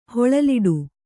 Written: ಹೊಳಲಿಡು

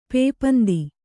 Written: ಪೇಪಂದಿ

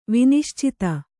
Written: ವಿನಿಶ್ಚಿತ

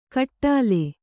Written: ಖಟ್ಲೆ

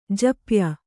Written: ಜಪ್ಯ

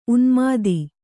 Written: ಉನ್ಮೋದ

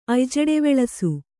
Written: ಅಯ್ಜಡೆವೆಳಸು

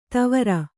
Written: ತವರ

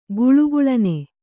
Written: ಘಲಘಲನೆ

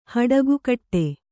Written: ಹಡಗುಕಟ್ಟೆ

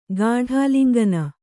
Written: ಗಾಢಾಲಿಂಗನ

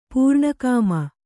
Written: ಪೂರ್ಣಕಾಮ

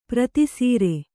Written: ಪ್ರತಿಸರ

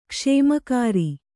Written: ಕುಸುಮಾಕರ

